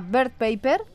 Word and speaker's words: Bird Paper: Bueno, recordamos, se llama Bird Paper, así bien pronunciado, como nos has dicho tú, Bird Pájaro Paper Papel.